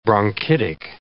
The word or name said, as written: bronchitic